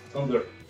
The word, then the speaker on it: Tumblr: ¿Cómo se dice? – Tumblr. – Tumblr.